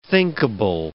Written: thinkable

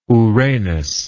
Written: ouranos

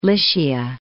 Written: Lycia

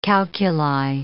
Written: calculi

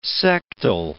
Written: sectile

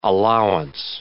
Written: allowance

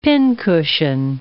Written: pincushion

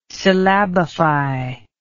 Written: syllabify

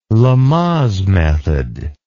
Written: lamaze method

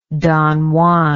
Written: don juan

